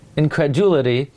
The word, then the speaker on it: incredulity: Incredulity. How do you pronounce it? Incredulity.